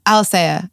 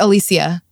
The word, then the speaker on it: Alicea: Like Alicea or like Alicia. Alicia.